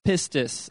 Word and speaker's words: pistis: The word faith in the Greek is the word pistis, all right?